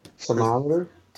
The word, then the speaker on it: thermometer: uh, guys, let's talk about a movie I was not expecting to have this high of a tom- tomato meter, but thermometer.